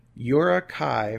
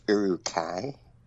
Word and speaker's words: Uruk-hai: Uruk-hai from The Lord of the Rings. I apologize. There's someone out there saying I said that wrong, I'm sure. I don't know. – Excuse me, it's Uruk-hai.